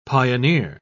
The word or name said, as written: pioneer